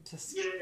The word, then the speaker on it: Tuskegee: Yeah, the Tuskogee Airmen, yeah, that black air group, yeah, that looks awesome. – It says really Tuskegee.